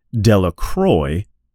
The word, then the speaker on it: Delacroix: Bobby and Harry Jones and Dicky Delacroix, the people in the town pronounce his name Dellacroy,